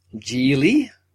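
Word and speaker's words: Gigli: If you remember last time, and our look at Geely, it rhymes with really, that sticks in my head, we decided to then move on to another one that we hope would be a bit better.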